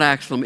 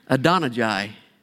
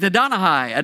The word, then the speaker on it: Adonijah: No, it's not Axelum. It is Adonijah. Somebody says, no. I think it is. I think it's Adonijah. Adonijah, Adonijah. A-D-O-N-I-J-A.